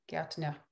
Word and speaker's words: Gärtner: Oh yeah, G-A-E-R-T-N-E-R. Gärtner.